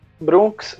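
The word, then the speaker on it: BRVNKS: Bom, para quem não conhece, Brunks nada mais é do que uma banda de rock que originalmente vem de Goiás. E como se escreve Brunks Não é da forma que eu estou falando, é B-R-V-N-K-S.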